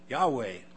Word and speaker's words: Yahweh: Yahweh, or Jehovah as some pronounce it, will provide.